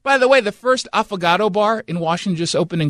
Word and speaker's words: affogato: So an affogato is what sounds like – avocado, but it's Italian, and it's spelled A-F-F-O-G-A-T-O.